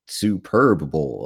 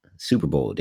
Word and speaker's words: Super Bowl: I'm like, you know, why do like Gordon Dameron keep saying Superb Bowl? Like, why is Rothenberg keep saying Superb Bowl? Like, Super Bowl, damn it. Say it right.